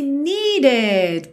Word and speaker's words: needed: E atenção aí para esse needed, porque esse E aí do meio é pronunciado, needed. Essa palavra tem mesmo duas sílabas, ok?